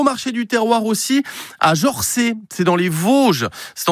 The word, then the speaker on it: Jorxey: Ça s'écrit J-O-R-X-E-Y. Attention sur le GPS. Hein. Si vous avez une ville vosgienne qui commence par un X, ça se prononce K2S. Xon rue Longemer. Si le X est au milieu, c'est 2S.